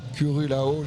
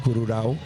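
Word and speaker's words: Currulao: Pixvae, c'est une musique hybride avec deux univers qui se confrontent et qui s'assemblent pour nous proposer une musique néo-traditionnelle autour du Curulao. Je prononce très mal. – Curulao.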